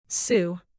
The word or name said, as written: Sioux